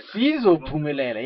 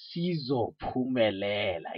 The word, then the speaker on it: Sizophumelela: So now we are into 2022, and you are also now currently promoting a song by the name of Impumelelo. I'm not sure if I'm saying it right. Impumelelo. – No, Sizophumelela. – Yeah, I want actually you to say it right. Sizophumelela.